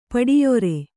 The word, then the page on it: ಪಡಿಯೊರೆ: Kannada